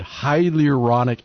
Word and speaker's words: hyaluronic: But apparently these guys are, you know, they're using my favorite fluid, hyaluronic acid.